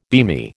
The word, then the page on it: beamy: American English